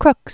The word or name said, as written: Crookes